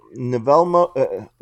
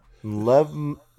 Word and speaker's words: Nlemvo: In 1882, Nevelmo, ooh, that's an interesting name. How would you pronounce that? Lemvo. Wow, that's cool. N-L-E-M-V-O. Lemvo.